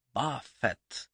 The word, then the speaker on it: баффет: Затем все скопом они отправлялись в «баффет» — ударение на первом слоге, «ударить по салатам», после чего выходили наружу на прогулку.